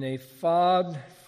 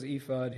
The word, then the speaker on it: ephod: And these are the garments they shall make: a breastpiece and a phod. So everybody says ephod here. It's the only Hebrew word in here, but it's pronounced a phod.